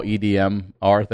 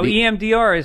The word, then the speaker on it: EMDR: But talking to Dr. Drew, he suggested this EMDR therapy, which I used to call EDMR therapy, which was completely wrong. And then I started thinking about electronic dance music, quit being a fucking idiot, you're saying it wrong.